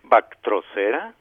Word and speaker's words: Bactrocera: Bactrocera.